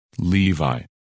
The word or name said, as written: Levi